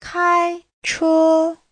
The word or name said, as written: kai1che1